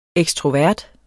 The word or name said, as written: ekstrovert